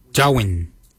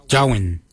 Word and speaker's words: Djowiny: Guard is spelled G-A-A-D. Guard. The next word is hair. For hair, we say Jowin. Jowin. Jowin is spelled D-J-O-W-I-N-Y.